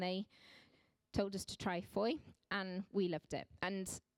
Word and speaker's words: Fowey: For anybody that hasn't been to Foy, it's spelled F-O-W-E-Y.